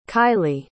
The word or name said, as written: kylie